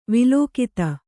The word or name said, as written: ವಲ್ಗಿತ